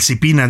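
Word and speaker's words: SIPINNA: El CIPINA, dije yo, Espina, lo pronuncié mal. El CIPINA, que es este sistema para proteger a los niños y adolescentes en México, Tania.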